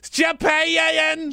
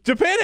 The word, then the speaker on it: Japan: How many syllables does he put into the word Japan? It's like Japan. – Japan. – Japan has two syllables. Japan.